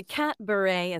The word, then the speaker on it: Catbaret: Am I saying that right? How did you develop that? – You are saying it right. Yes. So the Catbaret is a cat-themed variety show. So a pun on cabaret.